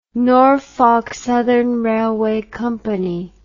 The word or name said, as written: Norfolk Southern Railway Co